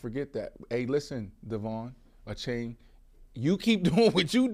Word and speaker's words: Achane: And going back, apparently his name is pronounced Achane.